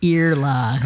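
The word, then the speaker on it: Iarla: And then he just said, Yarla, like your ear.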